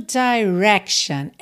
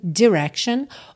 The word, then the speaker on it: direction: Essa primeira sílaba, ela pode ser com o I mais fechadinho, direction, ou com o I mais aberto, direction, tá? Na música, a gente tem quase que um híbrido, assim, fica direction, direction. Ela começa a abrir, mas não tem o direction, tá? Fica direction, direction. Mas não é direction fechadinho. Mas é bacana você saber que as duas pronúncias estão corretas.